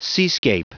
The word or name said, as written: seascape